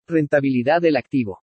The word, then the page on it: RENTABILIDAD DEL ACTIVO: Spanish